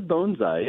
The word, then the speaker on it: bonsai: It's actually bonsai, yeah, okay. – Like a bone, like a dog bone.